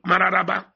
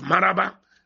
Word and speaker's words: Mararaba: I don't know how I feel anytime Daddy mentioned Mararaba instead of Maraba, as fondly pronounced in Abuja, though it is written and spelled as Mararaba, but fondly pronounced as Maraba.